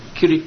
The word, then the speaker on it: Kirit: Kirit Patel. – Can you spell that, please? – K-I-R-I-T.